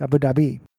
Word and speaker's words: Abu Dhabi: It's like Abu Dhabi versus Abu Dhabi. It's Abu Dhabi.